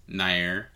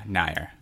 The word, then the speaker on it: Nair: And I think it's Mironair. – Nair. – Yeah, Nair. – Okay, I've always just assumed Nair, but I think that's just the American.